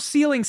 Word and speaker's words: sealing: I pronounced it wrong, and then you mimicked me pronouncing it wrong. – They stole their self sealing stem bolts.